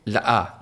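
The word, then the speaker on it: Le-a: Years before that, there was a story going viral that a woman called her daughter Ladasha, then told the teacher off for not pronouncing the dash because it was spelt L-E line A. – La-a.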